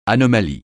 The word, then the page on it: anomalie: francais